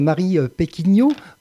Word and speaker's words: Marie Péquignot: Alors bonjour Marie Péquignot, c'est comme ça qu'on prononce votre nom d'abord, que je n'écorche pas trop. – Tout à fait.